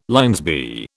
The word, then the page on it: LL B: British English